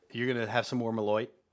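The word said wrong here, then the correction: Malört: Wait, did I say that right? – Malort.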